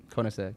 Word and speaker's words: Koenigsegg: I still think I liked, and I mispronounced this, so forgive me. It's the Koenigsegg. How do you? – Koenigsegg. – Koenigsegg? – Yeah.